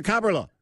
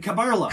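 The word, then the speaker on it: Kaberle: They changed it to Cabrillet. I remember that. – Cabrillet. – Cabrillet. – Because we had a recording of him saying, how do you say your name? And he says, Thomas Cabrillet. So we always said Thomas Cabrillet. And people would call it, you're saying his name wrong.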